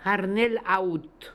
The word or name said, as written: Harnel Aoot